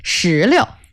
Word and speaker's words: 石榴: 石榴。 – 石榴。 – This word is that 石榴, the second character in 石榴 is the light tone.